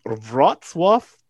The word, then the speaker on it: Wrocław: December 16th, 1985, when a baby girl joined the Urban family in Wrocław, Poland. I hope I pronounced that right, yeah? – You did, actually.